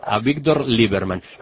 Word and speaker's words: Avigdor Lieberman: Ahora, una de las figuras más polémicas de este nuevo gobierno es el nuevo canciller, Avigdor Lieberman. ¿Se pronuncia así? – Te iba a decir justamente que lo pronunciaste perfecto. – Avigdor Lieberman.